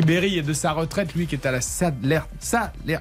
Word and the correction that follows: Salernitana: Ah, alors. pourtant, Salernitana. Pourtant, c'est italien, c'est facile à prononcer.